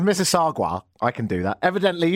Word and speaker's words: Mississauga: Mississauga is a dwelling near here in Toronto, part of the extended GTA, Greater Toronto Area, and it's pronounced Mississauga, unless you're Obama, who says, this is always tough. Mississauga. Mississauga.